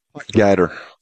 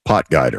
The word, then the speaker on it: Potgieter: Is it Aldrich Potgieter? – Potgieter. – One more time? – Potgieter. – Potgieter. Okay.